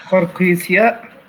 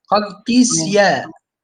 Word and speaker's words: قرقيسياء: قال ابن عساكر سكن جرير الكوفة ثم سكن قرقيساء. هي الياء اللي بعد القاف تنطق ولا هي ياء مادية؟ – قرقيسياء. – تمام. قرقيسياء.